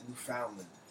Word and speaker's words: Newfoundland: Actually, I think it's Newfoundland. They pronounce it Newfoundland.